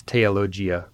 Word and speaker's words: theologia: By the way, in ecclesiastical Latin, the th consonant group is pronounced simply as a t. For example, it's theologia rather than theologia. That's why I pronounced the ablative form of the Latin word anathema as anathemate, in case anyone was wondering.